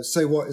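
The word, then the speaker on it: C'est What: So Say What is spelled in the French way, C'EST, Say What.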